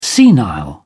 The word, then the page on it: senile: British English